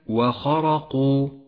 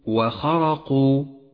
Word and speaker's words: وخرقوا: أخيرا لاحظ الألف في كلمة وخرقوا، وخرقوا، التي تسمى ألف التفريق، لونت بالرمادي لأنها تكتب ولا تلفظ.